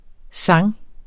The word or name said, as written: sang